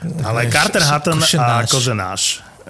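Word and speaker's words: Kořenář: Počúvaj, ty ho vyslovuješ jak tacha. Košenáš. Kozenáš. – Však on, on to Tak po, po polsky to dal.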